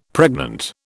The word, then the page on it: pregnant: British English